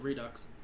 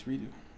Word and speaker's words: Redux: I'm still going to keep saying Redux. – It's Redo. It's... – Shouldn't there be an O in there then, if they're going to go with the French pronunciation of the X on the O? – It should be EAU. – Yeah. Oh, but no, you're right. EAU. – But it's Redo.